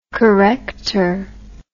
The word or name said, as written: corrector